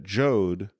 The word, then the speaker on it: Joad: Well, it's funny, like, there's a reason that Joad rhymes with Job.